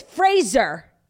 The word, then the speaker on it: Fraser: So he says, instead of a similar pronunciation similar to the 90s sitcom Frasier, Frasier says his name actually rhymes with razor. So it's Fraser.